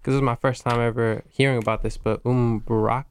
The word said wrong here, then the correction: Eid Mubarak: am I saying it right? Like, it's a holiday, it was in the... – Eid. Okay, I was at Eid Mubarak.